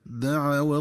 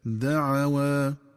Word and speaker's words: دعوى: وكذلك ينبغي التنبه للنطق الصحيح للفظ دعوى، فعند الوصل يقرأ بفتح العين وفتح الواو وحذف ألف الاثنين هكذا دعوى الله، وعند الوقف يقرأ بفتح العين والواو وإثبات ألف الاثنين هكذا دعوى، بصيغة التثنية لأنه عائد على الزوجين.